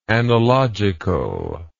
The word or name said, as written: analogicalness